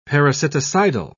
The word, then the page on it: parasiticidal: British English